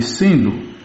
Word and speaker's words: Sindhu: O senhor sabe que existe um rio Indus, que em sânscrito chama-se Sindhu. Os maometanos pronunciam S como H, aspirado. Em vez de Sindhu, eles o chamavam de Indo.